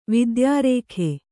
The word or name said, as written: ವಿದ್ಯಾರೇಖೆ